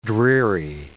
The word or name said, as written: dreary